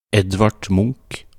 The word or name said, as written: Edvard Munch